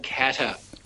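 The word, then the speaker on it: Katter: At number one, well, I would say what very highly ranked, there's a guy called Bob Catter. Now, it does actually rhyme with mad as a hatter, and that's what Bob Catter is.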